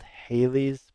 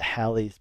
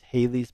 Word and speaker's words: Halley's: I really recommend a book called Haley's Bible Commentary. That's Haley's with two L's, by the way. So H-A-L-L-E-Y. Sometimes people call it Halley's Bible Commentary. I believe it's properly pronounced Haley's Bible Commentary.